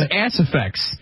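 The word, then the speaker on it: Aciphex: Once again, it's pronounced Acephex, and it treats heartburn, apparently.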